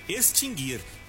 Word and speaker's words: extinguir: Isso mesmo, você não vai pronunciar o U no sentido de extinguir, guir. Não é assim, é extinguir. Você vai pronunciar como se o U não existisse nessa palavra.